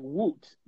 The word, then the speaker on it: Whut: Okay, so like Woot.